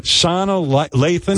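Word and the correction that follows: Sanaa Lathan: Sanai Lathan.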